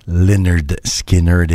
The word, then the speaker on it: Lynyrd Skynyrd: Pronounced Lynyrd Skynyrd et non pas Lynyrd Skynyrd.